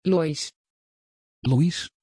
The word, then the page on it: loís: Dutch